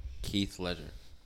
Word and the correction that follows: Heath Ledger: Heath Ledger. – Heath Ledger. You butchered that, brother.